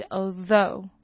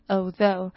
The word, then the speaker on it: although: 再来我们来看的是 although 这个字， although 是虽然，虽然 although 这个 th ough 这边呢就是发 though 的音， gh 都不发音， th 发 th 的音，所以是 although， although。